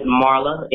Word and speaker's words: Marla: But anyways, my name is Marla, M-A-R-L-A.